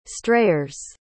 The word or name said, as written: strayers